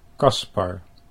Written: Caspar

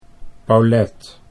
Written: Paulette

